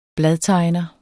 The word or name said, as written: bladtegner